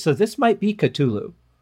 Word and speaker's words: Cthulhu: I always say Cthulhu, which I'm probably saying it wrong. – Well, you know, there's no H in it. And there, yeah, and it's with a K.